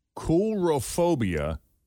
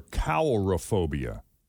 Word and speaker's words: coulrophobia: There's a couple of ways I can pronounce this. Coulrophobia or cowlrophobia. – How's it spelled? – C-O-U-L. – Cowl. – Cowlrophobia. – C-O-W-L-R-A. – C-O-U-L.